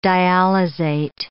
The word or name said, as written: dialysate